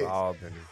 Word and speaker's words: Albany: Albany. – Did I say it right? – Did I say it right? – You said it right. – There it is. Albany.